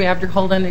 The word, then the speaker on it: Abderholden: Members of the committee, Sue Abderholden, Executive Director of NAMI Minnesota National Alliance on Mental Illness.